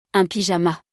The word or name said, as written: Un pyjama